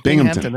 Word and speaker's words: Binghamton: Isn't it pronounced Binghamton? Am I saying that wrong? – Binghamton. That's exactly right.